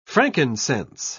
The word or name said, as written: frankincense